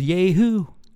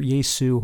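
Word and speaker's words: Jesu: And these days he's in his own band called Yehoo. Awesome band, or Yesu, however you want to pronounce it. Yesu. I like to say Yehoo.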